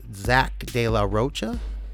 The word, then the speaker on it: Zach De La Rocha: So Zach De La Rocha, is that how you say his name? – Sure.